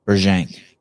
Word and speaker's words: Brzenk: How do you pronounce it? Berjank. – Berzank. – Berzank. Berzank. John Berzank. – I think Berzank's good. There's no burr. It's Berzank. Berzank.